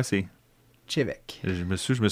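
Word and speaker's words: Té Bheag: peut, moi, je m'amuse à dire comme il est écrit sur la bouteille, c'est te bag. Un tea bag Puis ça se prononce Chevec. – Chevec, oui. Ouais.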